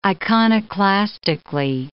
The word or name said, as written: iconoclastically